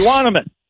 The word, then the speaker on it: Euonymus: Is it unimus bush? – Euonymus. – Say it again. – Euonymus. – Euonymus.